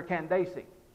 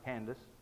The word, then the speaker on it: Candace: He was a eunuch of great authority under Candace. Some of us pronounce it Candace. My self-pronouncing Bible has three syllables.